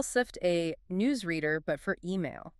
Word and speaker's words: Cypht: And just for the record, if you look it up, it's spelled CYPHT, Right, but it's pronounced SIFT.